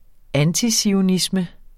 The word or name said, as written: antizionisme